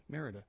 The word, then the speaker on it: Merida: Merida.